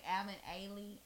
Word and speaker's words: Alvin Ailey: Alvin Ailey.